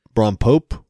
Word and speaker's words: rompope: R- R- Rom Rompope poppy, Rompopi Pope.